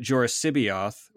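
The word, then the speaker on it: Joruus C'baoth: the entire premise was that Joris Sibioth, which is Tim's pronunciation, I would never have any idea how to pronounce it any other way, but I've asked Tim and that's how he pronounces it.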